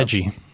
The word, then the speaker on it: Edji: Edji. Edji. – Spelled E D J I. – And everyone knows the uh, smaller, the, if you can squish it into, wow Two syllables, man.